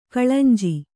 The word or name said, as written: ಕಳೆಂಜಿ